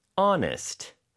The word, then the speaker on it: honest: Now, to be Honest. There's an H hiding at the beginning. H-O-N-E-S-T.